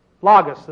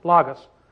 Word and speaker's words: Logos: Logos is pronounced in the Greek. Logos.